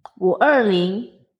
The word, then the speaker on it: 五二零: And it started because 五二零, which is how you say 520 or the 20th of the 5th in China. In Chinese, so that sounds like 我爱你.